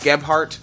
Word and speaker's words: Gebhardt: Oh, so I do pronounce the H. – Yes. – Gebhardt? – Yes. – Okay, Gebhardt. It's G-E-B-H-A-R-D-T for some reason. – Because it's German.